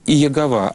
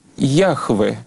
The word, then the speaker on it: Яхве: На самом деле оно читается не как Иегова, а как Яхве. Яхве, потому что Иегова — это неправильное прочтение вот той тетраграммы, тех четырех букв на иврите.